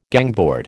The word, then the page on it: gangboard: American English